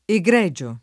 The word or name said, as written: egregio